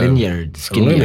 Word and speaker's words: Lynyrd Skynyrd: Lynyrd Skynyrd.